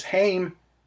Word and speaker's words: Haim: the third long player from the sisters Haim. You may pronounce that as Haim, H-A-I-M.